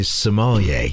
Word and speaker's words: sommelier: Ah, so you are a sommelier. Did I say it right? Sommelier? – That is correct.